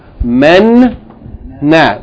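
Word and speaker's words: menna (منّا): Okay, it's two noons. The first noon has sukoon, so it's pronounced with the meme with fatta. Men. And then the second noon has the vowel fatta, na. Men, na. When you join it together, you say, menna. Menna.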